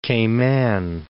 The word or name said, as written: Cayman Islands